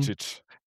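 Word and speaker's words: Ljubičić: oder wie wird er ausgesprochen? – Ljubicic.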